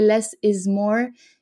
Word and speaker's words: Les Izmoor: Et c'est un jeu de mots parce que ça se prononce comme en anglais Less is More, mais ça s'écrit L-E-S espace I-Z-M-O-O-R.